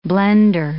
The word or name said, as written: blender